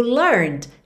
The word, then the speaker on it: learned: O learned está no passado, como a gente já viu, esse E não é pronunciado.